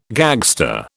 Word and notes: gagster: British English

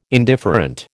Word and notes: indifferent: American English

